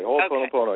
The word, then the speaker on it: Ho'oponopono: Ho'oponopono. – That's it. You said it perfectly. Ho'oponopono. – Okay. – You just pronounce every syllable.